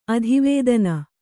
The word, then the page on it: ಅಧಿವೇದನ: adivēdana